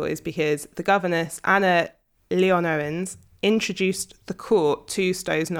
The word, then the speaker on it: Leonowens: Leonowens. – Yeah, it feels like you've got to say it all at once.